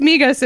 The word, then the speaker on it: Migos: Why are you saying Migo? – You're pronouncing it Migo like it's French? – Yeah. They maybe it's supposed to be French. – It's Migos.